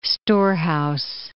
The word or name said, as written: storehouse